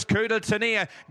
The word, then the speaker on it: coup de tonnerre: I didn't even try myself to pronounce it, but this is how it actually is pronounced. – Running home is coup de tonnerre.